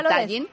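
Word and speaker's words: Tallinn: Tallin.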